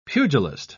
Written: pugilist

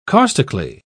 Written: caustically

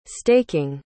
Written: staking

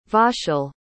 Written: vaschel

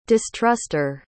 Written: distruster

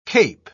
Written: cape

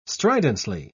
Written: stridently

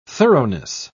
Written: thoroughness

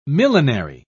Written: millenary

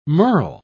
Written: merle

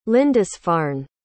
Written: lindisfarne

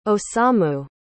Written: osamu

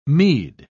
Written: mead

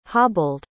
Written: hobbled

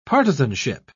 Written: partisanship

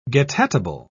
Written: getatable

